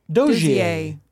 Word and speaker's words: Dozier: D-O-Z-I-E-R? – Yes. But this is how it's spelled phonetically. – Dozier.